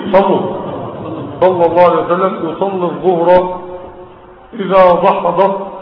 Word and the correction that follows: دحضت: وأنا أرجو أن تنطق هذه الكلمة الآن: دحضت. دحضت. كل واحد من اللي قاعدين شاب ولا شباب يقولها بينه وبين نفسه: دحضت. طب ليه؟ عشان تتعلم كيف تنطق الدال مع الحاء مع الحاء مع الضاد بعد داح ضاد.